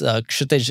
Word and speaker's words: Kshitij: So yes, Kshitij is not a very easy name to pronounce, but I didn't have a Christian name or an English name.